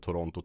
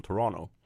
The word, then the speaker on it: Toronto: alla som bor i Toronto uttalar inte Toronto, Toronto, utan de släpper det sista T och så säger de Toronto.